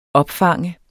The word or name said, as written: opfange